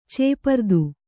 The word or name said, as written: ಚೇಪರ್ದು